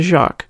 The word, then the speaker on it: Joque: The most common one for strap-on use is called the Jacques, and that's spelled J-O-Q-U-E.